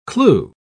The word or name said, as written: clue